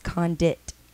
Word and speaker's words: Condict: It's pronounced Condit. The C in the end is silent.